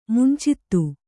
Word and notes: ಮುಂಚಿತ: muncita

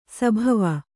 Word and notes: ಸಭವ: sabhava

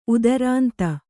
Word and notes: ಉದರಾಂತ: Kannada

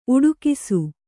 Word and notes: ಉಡುಗಿಸು: kannada

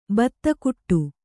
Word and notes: ಬೆದೆಗೂಡು: bedegūḍu

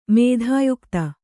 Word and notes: ಮಿಥೋಯುಕ್ತ: kannada

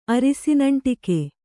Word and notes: ಅರಿಸಿನಂಟಿಕೆ: arisinaṇṭike

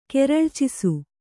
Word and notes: ಕೆರಳ್ಚಿಸು: kannada

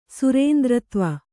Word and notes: ಸುರೇಂದ್ರತ್ವ: Kannada

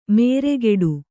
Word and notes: ಮೇರೆಗೆಡು: nēregeḍu

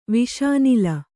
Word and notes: ವಿಷಾನಲ: Kannada

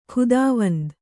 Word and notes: ಖುದಾವಂದ್: Kannada

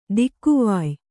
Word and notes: ದಿಕ್ಕುವಾಯ್: Kannada

